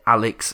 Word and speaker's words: Alyx: I believe it said Alex Vance, but it's spelled A-L-Y-X. – Yeah, so, it's Alex.